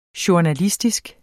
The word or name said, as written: journalistisk